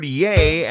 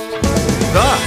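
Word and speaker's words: ye: And finally, the word yay, as in yay old shop, is actually pronounced the. Or the. In medieval English, people used a Y to abbreviate the T of the H.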